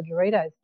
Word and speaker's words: Doritos: And I was going, gee, I don't know if this is CC's or Doritos. And then they kind of go, oh, rhymes with mosquitoes. So you go, oh, okay, it's Doritos.